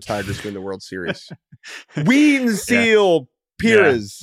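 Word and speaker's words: Wenceel: You know, we want to try and get pronunciations right, but the fact that it's W-E-N-C-E-E-L and is pronounced Wenzel or Wenzel not Wenzel.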